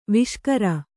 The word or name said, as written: ವಾಸಾಗಾರ